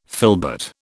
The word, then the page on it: filbert: British English